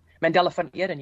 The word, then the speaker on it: Mandela von Eden: Did I say that right? – Yes, you did. Thank you so much, Ashley. I'm really, really honored to join you guys on the podcast today. Mandela von Eden, you pronounce it correctly.